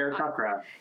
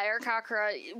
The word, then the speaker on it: Aarakocra: Aarakocra. – Yeah, Aarakocra, with, like, raven kind of look.